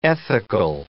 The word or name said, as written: ethical